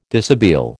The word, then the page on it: dishabille: American English